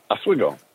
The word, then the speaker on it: Oswego: But he's from, how do I pronounce the New York State? Oswego? How do you say this, your hometown? – You've got Oswego? It right so far. Yeah, Oswego.